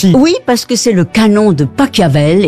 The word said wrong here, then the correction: Pachelbel: et tout le monde le connaît. – Mais non, c'est Pachelbel.